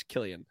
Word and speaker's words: Cillian: I've been trolled with this like several times with the Killian Silly. It's Killian.